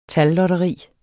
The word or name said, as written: tallotteri